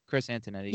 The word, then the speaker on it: Chris Antonetti: I love listening to – I'm going to mispronounce his name, but it's something like Antonetti, Chris Antonetti. – Yeah, you got it.